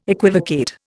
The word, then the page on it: equivocate: Indian